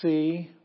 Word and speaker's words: psi: And then psi, which is a PS sound, as in lips it's the sound.